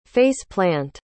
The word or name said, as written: face-plant